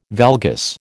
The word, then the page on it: valgus: American English